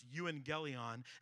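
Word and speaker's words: euangelion (εὐαγγέλιον): The word gospel actually comes from an old Greek word pronounced euangelion, and that word literally means a proclamation or a declaration.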